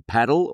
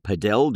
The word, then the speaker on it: padel: I've been playing a little bit more, got to pronounce this the right way, paddle or padel, depending on whether you're in Europe or Australia, how you pronounce it.